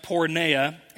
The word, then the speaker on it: porneia: What Paul's referring to when he talks about sexual sin, it comes from this Greek word pronounced porneia, and we derive our word pornography from that Greek word that's found quite a bit throughout the New Testament.